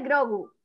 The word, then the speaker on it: Grogu: Dos, el futuro de Grogu.